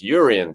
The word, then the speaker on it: Jurrien: But we've got to start off, haven't we, talking about what the hell is going on with Declan Rice and Jurrien Timber, I keep pronouncing the J and I've been told off for doing that in the comments section on YouTube. So apologies, Jurrien Timber.